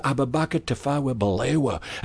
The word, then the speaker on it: Abubakar Tafawa Balewa: And then every Thursday we'd have a meeting with Tom McGee, and he'd go through the staff and he'd say, "You've mispronounced that, and you, how could you do that? It's Sir Abubakar Tafawa Balewa, and he's the Prime Minister of Nigeria, and the sooner you know that, you know."